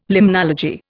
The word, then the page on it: limnology: Indian